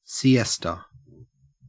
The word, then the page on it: siesta: Australian English